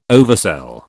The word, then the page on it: oversell: British English